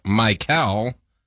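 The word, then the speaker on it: Mykel: Nope, Chris is Mikel Brown Jr.'s first name is pronounced Mikel, similar to the name Michael.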